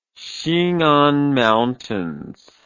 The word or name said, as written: xingan mountains